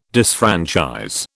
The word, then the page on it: disfranchise: British English